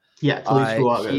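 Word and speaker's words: Taliese Fuaga: Another guy that I've been impressed with so far is, I don't want to butcher this first name, but Talese Fuaga. – Talese Fuaga is how you say it.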